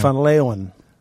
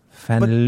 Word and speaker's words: Van Leeuwen: Say it so I know how to say it. Right. – Van Leeuwen. – Van Leeuwen.